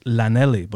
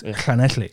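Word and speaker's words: Llanelli: So they have a town called, it's phonetically looks like Lanelli, but it's Clanetli.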